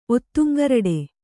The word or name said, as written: ಒತ್ತುಂಗರಡೆ